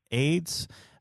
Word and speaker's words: AYDS: The last thing, and obviously the worst of them all, the diet candy, AYDS, pronounced AIDS, said lose weight with AIDS, which came out in 1980.